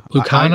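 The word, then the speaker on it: Lacuna: The company's name is Lucina I'm probably not pronouncing that right. Lucana, Lucana, Lucana. Lucana. I, I say it with a hard C.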